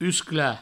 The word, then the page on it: usclar: uscla